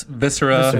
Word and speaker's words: Viscera: I don't know if I'm saying it right. Viscera? – It's Viscera. Viscera.